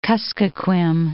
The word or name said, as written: Kuskokwim